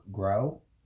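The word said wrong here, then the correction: Gow: Go. – Go? – Go. It's G-O-W.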